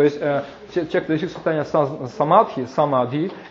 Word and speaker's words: самадхи: Самадхи, да? Нет, самадхи на санскрите звучит, а это самати, это уже несколько такие тибетские формы произношения этого слова.